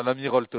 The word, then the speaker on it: Lamirault: C'est ça, l'Amiralt en prononciation, si Ouais.